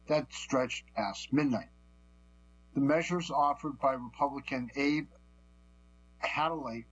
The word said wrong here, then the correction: Hamadeh: let me pronounce his name, Hamadeh, Republican of Arizona, were adopted in a tactical air and land forces N block passage.